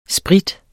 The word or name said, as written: sprit